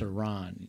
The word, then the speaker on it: Iran: Iran or Iran? – Which one is it? – I called it Iran. – It's Iran.